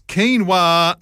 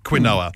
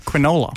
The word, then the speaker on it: quinoa: Number 4, it's quinoa, not quinoa. Now, I get this one because if you don't know the word, it's spelled Q-U-I-N-O-A. If you've never seen the word before and you first look at it, it looks like quinoa. – Mm. Quinola. – But it's actually quinoa.